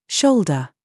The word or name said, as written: SHOE